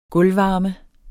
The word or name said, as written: gulvvarme